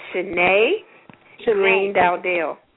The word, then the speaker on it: Shani Greene-Dowdell: Okay, it's Shani Greene-Dowdell.